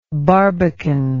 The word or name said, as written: barbacan